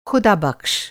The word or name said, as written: खुदाबक़्श